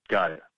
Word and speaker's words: Gaia: And if you think Goya terms, or Gaia, you know, I don't know. What is the correct pronunciation for that? – Gaia.